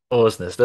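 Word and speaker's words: Aursnes: I'm going for Orsnes. – Ausnes. Let's stick with Ausnes. That sounds right.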